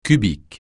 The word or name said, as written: cubique